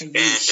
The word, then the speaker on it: Aayush: Aayush.